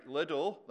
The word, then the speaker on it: Liddell: This morning, Jim told the story of Eric Liddell. That's how I would pronounce the name. I know he pronounced it with a different stress, a different emphasis.